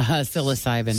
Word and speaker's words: psilocybin: And uh, a bag of uh, how do you pronounce this? Psilocybin mushrooms. Uh, psilocybin. – Psilocybin mushrooms. Yeah. Okay.